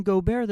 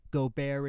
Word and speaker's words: Gobert: It rhymes with Eiffel Tower. Gobert is French.